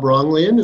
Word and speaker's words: Rognlien: Bob Rognlien is my name, in case it gets pronounced differently. It's kind of a tongue twister.